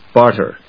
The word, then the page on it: barter: bάɚṭɚ; bάːtə